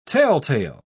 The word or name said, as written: telltale